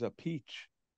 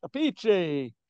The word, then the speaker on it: Appice: And he says a peach. That's the Italian way. A peach. A peachy.